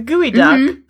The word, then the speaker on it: geoduck: Okay, so the gooey duck, spelled geoduck, not pronounced that way, because when you take words from one language and put them in another language, sometimes pronunciation's not intuitive.